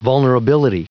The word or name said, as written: vulnerability